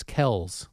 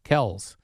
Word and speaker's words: Kelce: Well, I guess they confirmed on their podcast this week that the correct way to say their last name is Kells. – Kells.